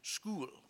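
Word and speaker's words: shul: The Yiddish word for synagogue is pronounced school, because it's a place of instruction.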